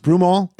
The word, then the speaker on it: Broomall: Is that how it's pronounced? Broomall? That's near Philadelphia, isn't it? Broomall? – Sure.